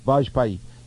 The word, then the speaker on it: Vajpayee: Vajpayee.